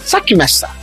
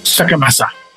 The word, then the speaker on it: Sackmesser: E em alemão, pronúncia correta é Schweizer Master Ou para vocês que tem alguma dificuldade no alemão, vocês podem falar Sackmesser, que é mais próximo ao dialeto suíço-germânico. Sackmesser.